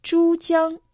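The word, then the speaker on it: 珠江: Чудян, Рига, Чудян. Первый иероглиф «чу» произносится в первом тоне. Переводится как «жемчужина». Второй иероглиф «тян» также в первом тоне.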